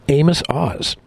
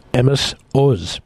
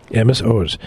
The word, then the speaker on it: Amos Oz: Chief among them was Aron's friend, the liberal Zionist literary lion, Amos Oz. Pardon me, I believe that's pronounced Amos Oz. Amos Oz.